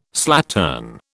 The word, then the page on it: slattern: British English